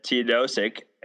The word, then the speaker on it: Teodosic: Um, what's a little scary is that Teodosic,